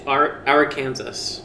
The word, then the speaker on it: Arkansas: You have pronunciation problems in the US as well, like Arkansas, not Arkansas. – It's Arkansas. – Arkansas. – No, it's Arkansas. – Arkansas, yeah, and we only knew that from Bill Clinton. – But then it's Kansas. What if it's Kansas? Why is it Arkansas? – I know.